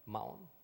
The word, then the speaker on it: Maon: And when David was told about it, he went down to the rock and stayed in the desert of Maon. Or how would you pronounce that, Pastor Bernard? Maon? – Sounds good. – Sounds good.